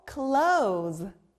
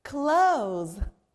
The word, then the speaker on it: close: 아, 닫다 Close에도 O's 소리 나네요. 바람이 너무 많이 불 때 우리는 창문을 꼭 닫아야 되죠. 닫다, 영어로 close. close.